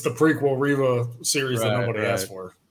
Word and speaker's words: Reva: Reva.